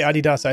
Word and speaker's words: Adidas: You say Adidas, I say Adidas. And yes, either way, doesn't matter how you say it, it's ridiculous looking.